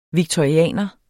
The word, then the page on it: victorianer: vigtoɐ̯iˈæˀnʌ; vigtʌiˈæˀnʌ